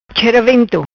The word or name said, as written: Chera Vento